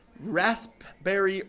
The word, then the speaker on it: raspberry: Today's tea is Ambiance, caffeine-free raspberry herbal tea. Very few people pronounce that P, and it's very important to me.